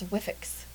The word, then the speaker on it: Wifex: It looks like Wifex. That's what I thought it was, too. Wifex. But then I listened to their podcast and quickly learned it's Wifex.